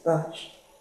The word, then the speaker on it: Barscz: Actually, it's Miss Bash. – Miss Bash, I'm Miss. Sorry. Can I spell that? B-A-R-S-H? – No, it's actually B-A-R-S-C-Z.